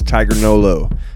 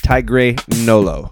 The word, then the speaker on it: TigreNolo: And for this edition of the playlist, we bring you an interview with our good friends TigreNolo.